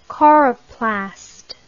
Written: coroplast

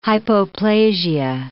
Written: hypoplasia

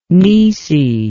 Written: naoise

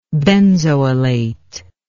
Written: benzoylation

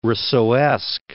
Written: Rousseauesque

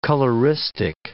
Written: coloristic

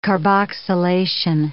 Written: carboxylation

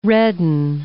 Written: redden

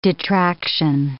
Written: detraction

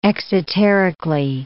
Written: exoterically

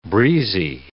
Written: breezy

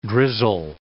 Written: drizzle

